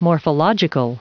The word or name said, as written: morphological